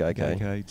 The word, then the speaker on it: Gaikai: No, I think that's how it's pronounced, Gaikai.